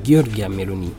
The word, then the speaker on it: Giorgia Meloni: Giorgio Meloni. – Giorgio. – Il faut le dire en italien. Giorgia Meloni. C'est ça.